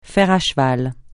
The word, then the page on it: fer à cheval: francais